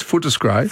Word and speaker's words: Footscray: Pronounce it properly. It's Footscray.